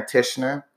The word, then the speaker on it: Tichenor: It's Boy, B-O-Y-A, and then Baddie is B-A-D-D-I-E. I'm also on Facebook, Aaron Tishner. It's A-A-Ron, and then Tishner is T-I-C-H-E-N-O-R.